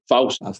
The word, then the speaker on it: Faustian: Faustian stories with a happy ending, because a lot of times they do not end happy, and it actually does here.